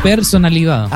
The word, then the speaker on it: personalidad: Tiene pechonalidad. – No, personalidad. – Ah, ya, personalidad, perdón.